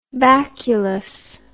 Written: baculus

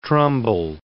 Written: Trumbull

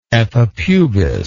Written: epipubic

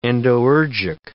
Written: endoergic

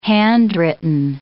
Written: handwritten

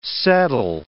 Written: settle

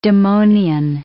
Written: demonian

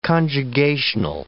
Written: conjugational

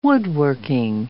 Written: woodworking

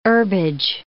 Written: herbage

